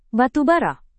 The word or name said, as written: batubara